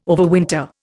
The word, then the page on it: overwinter: Indian